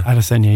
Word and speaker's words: Adesanya: Adesanya, yeah.